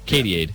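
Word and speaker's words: Katy-Aid: The 41-year-old pop star climbed into a giant transparent inflatable bottle nicknamed, I think this is how you say it, Katie-Aid? – Yep, like Gatorade, but Katie-Aid? – Yeah, Katie-Aid.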